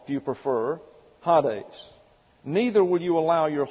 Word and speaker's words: Hades: And this is the Greek word, H-A-D-E-S, Hades. And this is a eta here, and it gets an A sound in the Greek, but when it comes into the English, we pronounce it like our E, and we get Hades.